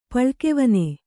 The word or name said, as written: ಪಳ್ಕೆವನೆ